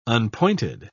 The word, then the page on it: unpointed: British English